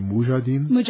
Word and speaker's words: Mujahideen: Mujahideen.